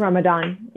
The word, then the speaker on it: Ramadan: Ramadan.